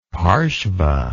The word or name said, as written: parsva